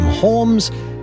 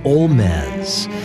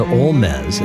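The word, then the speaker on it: Holmès: she changed the pronunciation of her last name from Holmes to Olmez. We'll hear a gorgeous piece by Augusta Olmez.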